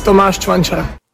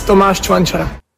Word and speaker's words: Tomáš Čvančara: This is how you say his name. – It's easy. Tomáš Čvančara. – What was that? I never caught that. – It's easy. Tomáš Čvančara.